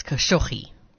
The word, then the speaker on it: Khashoggi: Yeah, it says on YouTube it's Khashoggi.